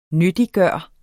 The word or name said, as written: nyttiggøre